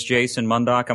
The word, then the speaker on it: Jason Mundok: My name is Jason Mundok.